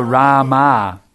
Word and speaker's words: Rama: It's actually a short A. It's not Rama, it's Rama. Rama.